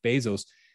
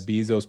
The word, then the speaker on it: Bezos: Amazon without thinking of Jeff Bezos,